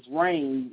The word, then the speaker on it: Raine: It's T-S-A-L-M-I-S-T. Last name is Rain, like R-A-I-N-E.